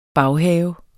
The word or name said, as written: baghave